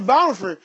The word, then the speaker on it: Bonhoeffer: In fact, Bonhoeffer, what is that how you pronounce his name? – Yeah, Dietrich Bonhoeffer.